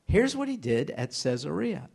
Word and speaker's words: Caesarea: If you go to Israel today, you'll hear it pronounced Caesarea, Caesarea. And you'll say, what in the world? That's Caesarea. It's named after Caesar, Augustus Caesar, who was a patron of a fellow named Herod the Great.